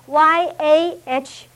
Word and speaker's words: Yahweh: 神的名是 YHWH，但是加了母音以后 YAHWEH 就变成亚卫。